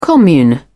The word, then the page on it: commune: British English